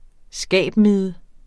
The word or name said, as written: skabmide